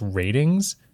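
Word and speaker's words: Rtings: A website called, I believe it's pronounced Ratings. It's R-T-I-N-G-S.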